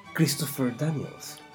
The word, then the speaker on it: Christopher Daniels: Christopher Daniels.